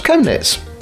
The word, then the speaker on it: Chemnitz: Now, Chemnitz, however, is pronounced Chemnitz, and nobody seems to know why.